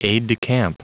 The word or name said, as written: aide-de-camp